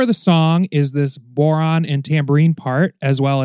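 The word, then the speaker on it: bodhrán: which is called the boron. It's pronounced a few different ways. I've heard boron, bowron, baron, bowron. It's spelled B-O-D-H-R-A-N, and there's an accent over the A.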